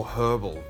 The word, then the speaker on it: herbal: And because it's all herbal, basically, and that is the correct way to say it, herbal, because there's an H in the word, it brings it all out.